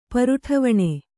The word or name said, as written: ಪರುಠವಣೆ